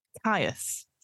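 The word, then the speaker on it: Kyuss: It's K Y U-S-S. – Kyss. – Kyss. Spawn of Kyss. I think that's how you pronounce it. Caius. Sorry, Spawn of Caius, according to Google pronunciations.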